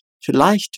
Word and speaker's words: vielleicht: Vielleicht wird mit dem Sch ne, von ich ausgesprochen.